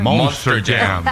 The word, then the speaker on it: Monster Jam: Mo- Manta- ¿Y cómo se dice? – Monster Jam.